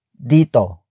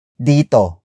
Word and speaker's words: dito: It is followed by dito, dito, dito. Dito is spelled as D-I-T-O.